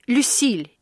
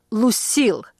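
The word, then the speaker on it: Lucille: Как в песне «Люсиль» или «Лусил», как произносят это имя американцы.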